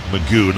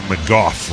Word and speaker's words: McGough: For the record, his name is spelled M-C-G-O-U-G-H. – That's okay. – Magoo, not McGough.